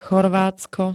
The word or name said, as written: Chorvátsko